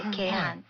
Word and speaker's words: 鶏飯: 食べ物は奄美では鶏飯という郷土料理が大変私は大好きで、はい、鶏飯と書いて鶏飯っていうんですけれども、